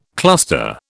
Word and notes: cluster: British English

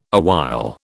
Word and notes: awhile: British English

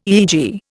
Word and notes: EEG: Indian

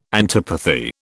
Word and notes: antipathy: British English

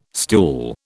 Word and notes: stool: British English

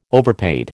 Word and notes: overpaid: American English

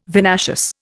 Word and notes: vinaceous: Indian

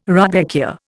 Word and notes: rudbeckia: Indian

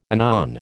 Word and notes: anon: American English

